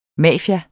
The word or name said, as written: mafia